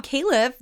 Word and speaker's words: Califf: Speaking of other big biotech news of the week, Rob Califf, the FDA commissioner nominee, had his Senate confirmation hearing.